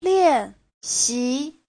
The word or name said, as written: lian4xi2